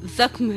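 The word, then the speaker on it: ज़ख़्म: ऐसा ही एक लफ्ज है जख्म, जिसे हम अक्सर बोलचाल में जख्म या जख्म कहते हैं, लेकिन इसका सही उच्चारण, सही प्रोनंसिएशन या कहें सही तलफ्फुज जख्म है।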